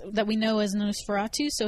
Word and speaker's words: NOS4A2: So this show right here is pronounced. It's NOS4A2. Is that correct? – Nosferatu.